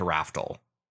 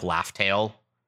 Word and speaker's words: Raftel: So she now absolutely shares the goal of getting to Raftel. – Raftel is how it's pronounced. – I've heard that there's differentiations on like even being called like Laugh-tail.